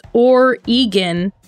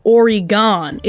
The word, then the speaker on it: Oregon: Just remember that it's Oregon, not Oregon. It literally says G-A-W-N. It was written for gone. – People with our accent.